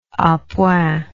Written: apoint

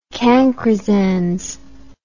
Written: cancrizans

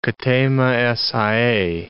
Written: ktema es aei

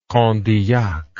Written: condillac

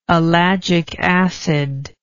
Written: ellagic acid

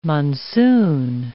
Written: monsoon

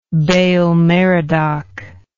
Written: baal merodach

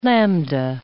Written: lambda

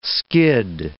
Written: skid

